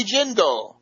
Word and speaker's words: Jindal: Bobby Jindal rhymes with swindle.